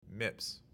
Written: MIPS

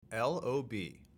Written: LOB

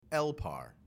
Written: LPAR

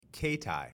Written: keitai

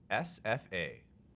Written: SFA

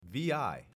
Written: vi

